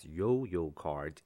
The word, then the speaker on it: 悠游卡: 最后再介绍它的使用方式。所以我们就说 ,The Easy Card, the smart card used in the Taipei MRT system, is pronounced as Yo-Yo Card in Chinese.